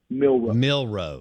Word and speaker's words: Milroe: Jalen Milroe. Milroe. He is the Milroe. M-I-L-R-O-E.